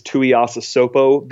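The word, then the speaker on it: Tuiasosopo: And if not, is Tuiasa Sopo the answer? – Wow.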